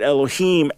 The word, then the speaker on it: Elohim: Or in this case, you're going to pronounce it Elohim, as indicated by the vowels before it and afterwards.